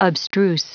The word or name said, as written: abstruse